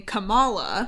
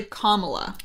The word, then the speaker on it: Kamala: So I was saying Kamala. – Mm-hmm. – It's actually Kamala. – Kamala. – There was a really cute video of kids saying, it's not Kamala, it's not Kamala.